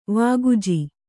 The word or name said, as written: ವಾಗುಜಿ